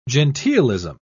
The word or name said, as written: genteelism